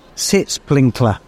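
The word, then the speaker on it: Sitzpinkler: Sits Pinkler. I said it wrong. Sits Pinkler. – Sits Pink. – S-I-T-Z. – Yeah. – Pinkler. – Yeah. – Sits Pinkler, all one word.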